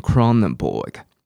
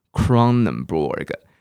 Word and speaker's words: Kronenbourg: 结果根本不是什么 one six six four，要念成 Kronenbourg。 Kronenbourg，所以跟我念念看，Kronenbourg，OK。那后来我就查一下，原来 Kronenbourg 它是一个酿酒厂，好，啤酒的酿酒厂我们叫 Brewery，Brewery，好，所以你就可以不用管它怎么拼。